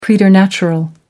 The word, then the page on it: preternatural: American English